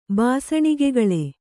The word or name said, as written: ಬಾಸಣಿಗೆಗಳೆ